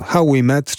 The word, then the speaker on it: How We Met: Otóż nazwę zespołu How We Met pisze się H-O-W spacja W-E spacja My et. Tak jak się słyszy, tak się pisze ostatni człon. How We Met, czyli How We Met.